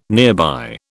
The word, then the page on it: nearby: British English